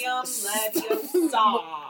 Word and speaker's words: Leviosa: It's Leviosa. – Accent on the saw, you fuck.